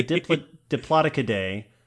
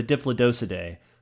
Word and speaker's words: Diplodocidae: So the two ways to pronounce the family, right? You can say the Diplodocidae or the Diplodocidae.